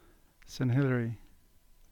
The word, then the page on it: Saint Hilary: English